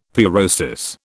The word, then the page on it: pyrosis: British English